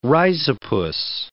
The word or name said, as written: rhizopus